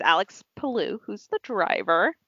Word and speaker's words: Palou: Palou with Team Go. – Which is, yeah, G-O-H, but it's hilarious.